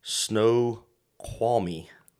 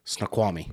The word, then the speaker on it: Snoqualmie: Snoqualmie. – Snoqualmie. – Yeah. – Snoqualmie. Sorry, I knew I was going to struggle with that. I even called him to learn how to pronounce it. I still messed up.